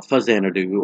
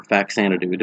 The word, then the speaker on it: Faxanadu: its sequel, Fuzanadu or Faxanadu, depending on how you pronounce it.